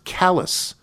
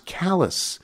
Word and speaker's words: Calais: Calais, Maine, C-A-L-A-I-S, is pronounced callous. C-A-L-A-I-S, pronounced callous.